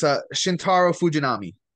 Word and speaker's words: Shintaro Fujinami: Can you pronounce this name? Because I can't. – Oh, yeah. It's Shintaro Fujinami. Fujinami. Shintaro Fujinami.